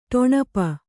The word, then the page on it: ಟೊಣಪ: ṭoṇapa